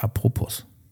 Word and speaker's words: apropos: Man sagt immer so apropos von Popo, aber es heißt apropos. – Aber das S spricht man ja nicht.